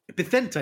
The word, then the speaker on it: Vicente: How about Vicente? Is that better? – Vicente is correct. – Yeah. I love it when Vicente.